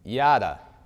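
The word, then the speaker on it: yada (ידע): In Hebrew, the word is yada, or that's how I pronounce it, yada, which means to know intimately, to know the insides out.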